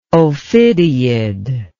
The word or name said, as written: ophidiid